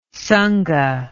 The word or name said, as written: sangha